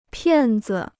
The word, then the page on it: 骗子: piàn zi